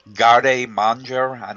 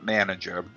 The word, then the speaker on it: garde manger: Garde manger. It's not manager.